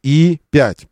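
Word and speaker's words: E5: Кай, Каи и 5. Пишется как Е5, но произносится И5.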